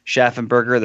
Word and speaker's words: Schaffenberger: often that particle Schaffen is anglicized to Schaefer or Schaffen in English. But since Mr. Schaffenberger is a first-generation German immigrant. He probably might have retained something closer to the original German pronunciation. So I'm going to go with Schaffenberger for the rest of this episode.